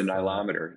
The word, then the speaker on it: nilometer: Uh, and when you got on the phone with us, you told us a, a story, the the millimeter uh, story of oh the nilometer.